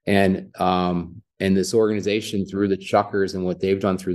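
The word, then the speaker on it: Chukars: you look at it, you could think it's pronounced differently, but they are the Chuckers.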